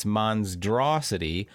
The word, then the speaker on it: MonsDRAWsity: Yeah, it sounds like monstrosity, but it's Monsdrosity. Monsdraw. S-I-T-Y, Monsdrosity, because you will be drawing in this game, and that's part of the fun.